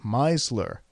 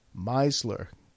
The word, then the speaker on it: Meisler: Now, the funny part about this is that my name is Meisler. M-E-I. Meisler.